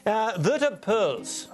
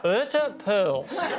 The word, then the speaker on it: Wouter Poels: How do you say his name again? – Wouter Poels. – One more time. – Wouter Poels.